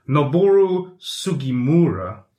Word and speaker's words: Noboru Sugimura: And the Resident Evil writer, okay, oh God, here goes my pronunciation. Noboru Sugimura. – Nice.